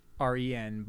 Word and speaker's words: Bohren: It's like Bohren, Baharan.